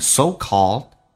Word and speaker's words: so-called: 来看一下 call, 打开口腔啊, 再加个 L 这个发音 so-called, 最后一个 D 不发音 so-called failure。